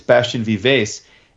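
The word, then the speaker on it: Bastien Vivès: And Vince was butchering the name. It's Bastien Vives.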